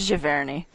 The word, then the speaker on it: Giverny: We started a unit on Charlotte in, I'm probably going to pronounce it wrong, Giverny? – I think it's Giverny.